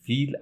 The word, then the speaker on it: پیل: ما میگیم فیل، اصلش پیل بوده. عرب ها چون پ رو نمیتونن تلفظ کنن میگن ف، ما هم به پیل میگیم فیل، به گوسپند میگیم گوسفند، به پارسی میگیم فارسی. حالا فیلم از اوناست.